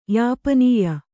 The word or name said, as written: ಯಾಪನೀಯ